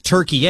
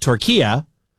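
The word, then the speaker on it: Türkiye: We just go with it because, again, much like last year or last hour, I learned that it's not Turkey, it's Turkia, which is the correct way to pronounce what we have been calling Turkey, the country, for a number of years.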